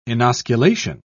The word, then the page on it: inosculation: British English